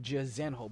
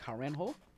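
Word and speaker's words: Jairzinho: But Francis Ngannou takes on, I'm going to call him Jarzenho, but that's not how they pronounce his name. It's like Harenho.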